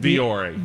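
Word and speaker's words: Vuori: Yeah, wouldn't you think, like, you also carry, I never pronounce right, Vior? Is it Viori? – Viori.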